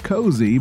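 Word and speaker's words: Koazy: It's pronounced Cozy, but it's spelled K-O-A-Z-Y. I mean,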